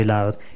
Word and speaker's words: Louth: County Louth, in Ireland. That's the Free Radio Show, Radio Rainbow International, 151 Moneymore, and Moneymore is spelled M-O-N-E-Y-M-O-R-E, Moneymore, Drogheda, D-R-O-G-H-E-D-A, Drogheda, County Louth, County as in C-O-U-N-T-Y, and Louth is spelled L-O-U-T-H, Louth in Ireland.